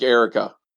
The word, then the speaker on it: Eureka: It is, and if I remember correctly, it's not pronounced Eureka. – It probably isn't. – Eureka? – No, it's more like Erica.